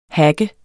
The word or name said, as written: hacker